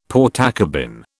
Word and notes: Portakabin: British English

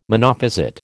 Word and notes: Monophysite: American English

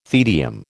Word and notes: tedium: American English

